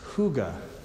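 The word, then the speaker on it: hygge: And in Denmark, there's also this word, and the word, it's pronounced Hooguh, which I looked up how to pronounce it because it's spelled like H-Y-Y something, you know.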